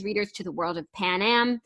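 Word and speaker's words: Panem: It's Pan Am. It's Pan Am.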